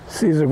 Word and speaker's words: Caesar: Cesar Graham. – Can you spell that for me? – C-A-E-S-A-R.